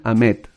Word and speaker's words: Ahmed: les recomiendo sin ninguna duda Sultán Ahmed. Sultán Ahmed. Así, me enseñaron que la H intermedia se pronuncia como J, entonces es Ahmed.